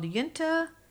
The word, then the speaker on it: Uinta: And there's another one called Yunta Brewing Company. So I'm going to spell that UINTA Brewing Company.